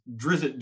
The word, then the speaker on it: Drizzt: Um, but even even with that, like as a kid, I think I I think for a little bit of time, I pronounced Driss Duorden as Drizzt Duorden. – Yeah, Drizzt is it.